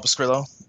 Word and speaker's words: Pescrillo: And you can find me at Paul Pescrillo.